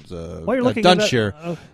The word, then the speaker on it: Dunshire: It's the Towers of Dashir, I think it's. I may be saying that wrong. The Cones of Dashir. Dunshire. That's it.